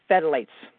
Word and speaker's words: phthalates: It's got chemicals called phthalates.